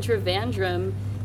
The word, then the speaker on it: Trivandrum: Yeah, who I have just met on the Rickshaw Challenge, which we have just finished here in Trivandrum, Kerala, India.